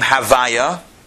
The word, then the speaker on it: Havaya: which we never pronounce the way it's written the, we do pronounce it. We switch around the letters and we pronounce the name Havaya.